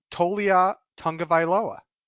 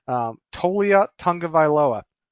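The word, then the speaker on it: Taulia Tagovailoa: Plus, they got Tolia Tungavailoa. And that is pronounced correctly, by the way, for all of you Rundown Nations waiting for me to mispronounce the name. Tolia Tungavailoa.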